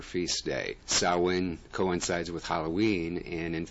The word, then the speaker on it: Samhain: It's actually pronounced Samhain.